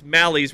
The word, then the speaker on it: Malley: We always remember how to pronounce Malley's last name by remembering it rhymes with 10-run rally.